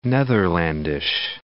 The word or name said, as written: Netherlandish